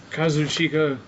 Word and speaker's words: Kazuchika: Kazuchika. – Kazuchika? – Oh, it's Okada. – Where is it? – Okada. Just say Okada. – Kazuchika.